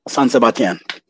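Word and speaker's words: San Sebastián: San Sebastian.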